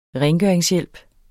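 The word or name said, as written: RENGØRINGSHJÆLP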